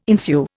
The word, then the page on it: ensue: Indian